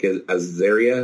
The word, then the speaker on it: Azaria: I really like uh, Hank Azaria.